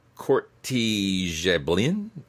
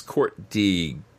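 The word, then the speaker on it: Court de Gébelin: In 1781, the French-Swiss Protestant clergyman and occultist Antoine Court, who named himself Courtigeblin, I believe is how that's pronounced. It could be Gebelin, but I don't think so. It's Courtigeblin.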